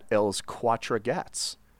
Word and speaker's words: Els Quatre Gats: So Picasso spent most of his time in Barcelona at Els Quatre Gats, the Four Cats. And that is the Catalan pronunciation. That's not me butchering basic French and Spanish.